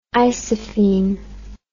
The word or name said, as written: isophene